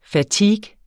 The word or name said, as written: fatigue